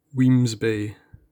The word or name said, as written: Wemyss Bay